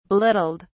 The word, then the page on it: belittled: British English